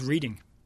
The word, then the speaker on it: Reading: Anurag Anjaria scouting Andrea Novakovic, 22-year-old American striker on loan at Fortuna Sittard from Reading, although that loan has now completed. – It's pronounced Reading.